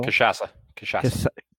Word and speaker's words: cachaça: The national liquor of El Salvador is Tic Tac, which is a sugarcane distillate, very similar to cachaca, which is from Brazil. – Cachaça. – I'm struggling with my pronunciations today.